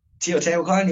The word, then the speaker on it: Teotihuacan: Teotihuacan. – Teotihuacan.